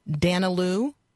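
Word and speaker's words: Daniélou: Okay, so in this piece, there is discussion of a person who with whom I am unfamiliar, and so I may mispronounce this individual's name. Danilou? – Yeah, good job.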